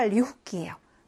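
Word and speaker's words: 리호끼 (лёгкий): 첫 번째 아이는 형용사 리호끼예요. 그래서 이때 이 그가 흐 발음이 납니다.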